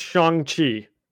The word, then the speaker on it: Shang-Chi: I'm listening to a thing right now on it. Shang-Chi. It's Shang-Chi. – Okay. – That's not that hard to remember. – It's not Shang-Chi.